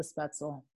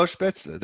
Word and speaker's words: Spätzle: I probably mispronounced the Spätzle. – No, Spätzle. That's right.